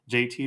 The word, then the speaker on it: Jaeti: So the best way to find me is just Google JT, and it's spelled J-A-E-T-I. That's J-A-E-T-I. My name is JT, and you can Google me, and when you Google me, I'm the first thing that pops up.